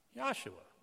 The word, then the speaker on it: Yahshua: Change the J to a Y, and how do you pronounce Joshua? Yahshua.